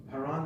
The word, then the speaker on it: Haran: Haran, the brother of Abraham. The H is like our H in English.